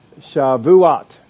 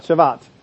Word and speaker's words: Shavuot: Now, the Feast of Weeks or the Feast of Pentecost, in the Hebrew, they called it the Feast of, and I will try and get this right, Shavuot. Not Shavat. The pronunciation is the three-syllable Shavuot.